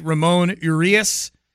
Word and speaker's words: Ramon Urias: Ramon Urias is what I'm being told it's pronounced.